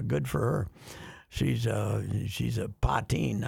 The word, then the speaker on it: Puttin: So the Gophers just hired a young lady for their sports information department, and her last name is spelled P-U-T-T-I-N. – No. – P-U-T-T-I-N. – Uh-oh. – So Putin. I checked. – Putintang. – She pronounces it Poteen.